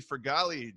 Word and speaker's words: Fragale: Today, I am joined by my new friend, Jesse Fragale.